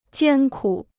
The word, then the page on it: 艰苦: jiān kǔ